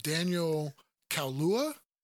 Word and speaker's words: Daniel Kaluuya: I could absolutely see him, Daniel Kaluuya.